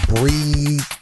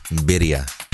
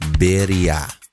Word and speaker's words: birria: Let your Mexican side come forth. – Birria. – What? – Birria. – Birria? – Birria. – It's spelled B-R-I-A, right? – No. – It's spelled B-I-R-R-A. – B-I-R-R-A. – If you wanna white person it up, it's birria.